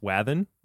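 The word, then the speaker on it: Wathan: I will say to some of y'all that his name is pronounced Wathen, right? That's right, right? – Yeah, Wathen. You got it.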